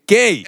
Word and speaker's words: GUEI: 哎，这个 Google 小姐告诉我们，这个发音念 gay，所以你是 gay 四二。 – 我们还是讲 G U E I 四二就好。 – 啊，如果我们有念错您的大名。请私信或者再留言一次。好，这个 gay 四十二的留言啊，